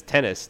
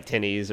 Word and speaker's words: Tennys: I can't think of what his last name is anymore, but his first name is Tennis, TENNYS, and I'm like, but I think it's like pronounced like Tinnies or something.